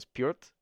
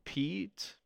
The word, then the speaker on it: Piotr: It was spelled P-I-O-T-R. And people would say, so Pete? Piotr? And he said, no, it's Piotr. Because there's an R at the very, like, the last half syllable is an R.